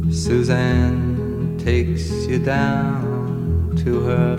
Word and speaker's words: Suzanne: And of course, that song had to be Suzanne, or Susanna. I guess you kind of pronounce the E at the end.